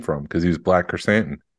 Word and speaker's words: Krrsantan: hey, you said it right. – Krrsantan.